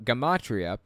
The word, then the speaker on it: gematria: You're talking about gematria, pronounced with a hard G.